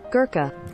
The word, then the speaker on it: Gurkha: How do you pronounce that? Gurkha? – Gurkha. – Gurkha. A Gherkin veteran, a Gurkha veteran who lost both legs in Afghanistan, has achieved an amazing feat by reaching the top of Mount Everest.